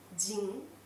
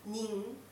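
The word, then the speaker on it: jin (人): Et puis la prononciation, donc il y a jin, nin ou alors hito.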